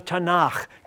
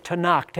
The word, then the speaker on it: Tanakh: for which where we get the word Tanakh, taking those three syllables, the T for the Torah, the N sound from Nevi'im, the prophets, and the Ch or the K sound from the writings of the Proverbs, etc., Ketuvim. And